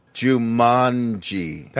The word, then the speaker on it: Jumanji: Jumanji. It's a long A. – Jumanji. – Jumanji.